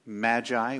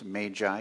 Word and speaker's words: Magi: The word Magi, which can also be pronounced Magi, you may hear it that way sometimes, they're both correct.